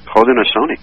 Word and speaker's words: Haudenosaunee: Haudenosaunee.